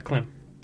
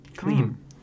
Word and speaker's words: Klim: Valeno scores a goal, assisted by Christian Fisher and Klim Kostin. It's Klim, not Klim. Klim.